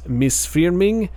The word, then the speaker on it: Misþyrming: But I'm going to try to pronounce it right because one of these characters that kind of resembles a P, uh, I keep hearing them pronounce it like an F, so it could be wrong, but this is what I'm hearing when I look it up. So the band is Misfirming and I think that means like um, mistreatment in Icelandic.